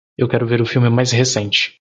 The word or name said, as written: recente